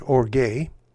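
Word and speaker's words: orge (ὀργή): The pronunciation of this word is orge, and it means abiding anger.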